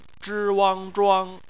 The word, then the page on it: zhuang: bpmf